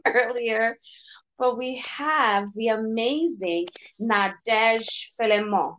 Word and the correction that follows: Fleurimont: right? Nadège Fleurimont. Fleurimont. Okay, there we go.